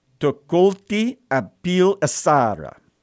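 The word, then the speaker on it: Tukulti-apil-Ešarra: His properly pronounced name, as much as I can pronounce ancient Assyrian, is Tukulti Apil Asara. But that's not how we're going to know him. We're going to call him by the English butchering of that name, Tiglath-Pileser.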